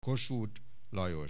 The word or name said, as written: Kossuth, Lajos